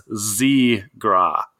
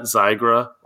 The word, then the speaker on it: Zegra: She also wanted to say, I think sometimes we might mix up our pronunciations, that she did want to confirm it is Zegra, not Zygra or anything like that, which we, who knows? – I don't know what you're talking about.